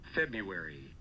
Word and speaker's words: February: Number one preferred. – February. – No R. – That's horrible. – February. – What? – Oh. – Why would there be a silent R? – February.